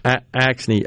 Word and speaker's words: Axne: Rhino. – Axne, A-X-N-E, Representative Axne.